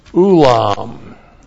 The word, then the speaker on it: ulam: And then we have ulam. Looks like that in the Hebrew. Aleph, long U-L-A-M. Ulam. And ulam means porch, and this refers to the porch of Solomon's temple.